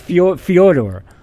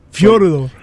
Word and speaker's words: Fyodor: It's Fyodor. – Yeah. – But Fyodor.